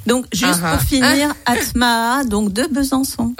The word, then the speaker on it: ATM2A: Enfin, regardez, on est là, Atmaa. Ça s'écrit ATM2A.